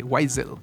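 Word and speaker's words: Waizel: Uriel Weisel, mi apellido, bueno, se le pueden preguntar a Alonso, pero es W-A-I latina Z-E-L, Weisel. – Weisel, sí, se pronuncia así. – Weisel, si lo quieres germanizar, es Weiser. Si es anglificar, Weisel.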